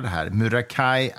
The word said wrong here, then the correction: Muraki: Atsuko, heter det så? Heter hon så? – Muraki. – Muraki. Muraki Atsuko.